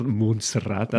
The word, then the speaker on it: Montserrat: I love your Catalan pronunciation of it, Montserrat.